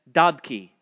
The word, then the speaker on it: Dadcu: We'll consider him finally as Dadcu, is the best I understand how you are to pronounce it, D-A-D-C-U.